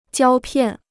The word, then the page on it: 胶片: jiāo piàn